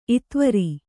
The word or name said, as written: ಇತ್ವರ